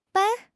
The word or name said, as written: ban1